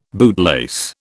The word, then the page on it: bootlace: British English